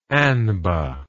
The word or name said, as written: anba